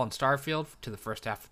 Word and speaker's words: Starfield: Starfield and Redfall. I said it right. I always want to say Starfall and Redfield.